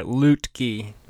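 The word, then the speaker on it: Luetge: It's kind of like Loogie, but there's you do pronounce the T in there. Lutke.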